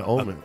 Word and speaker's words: Homens: Homen, did I say it wrong? Homen? – Yeah, Omen. – Omen. Oh, yeah. – He's saying Omen. She's saying I forgot, like, drop the H.